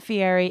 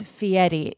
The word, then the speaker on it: Fieri: it's not Fieri, it's Fieri. You have to pronounce it the Italian way.